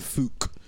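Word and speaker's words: Fuchs: Because I've got the back four of Simpson, Morgan, Huth and Fouque, they pronounce it.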